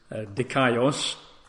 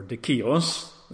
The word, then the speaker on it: dikaios: The Greek word is dikaios, or dikios. We spell that in English as D-I-K-A-I-O-S, D-I-K-A-I-O-S, dikaios or dikios.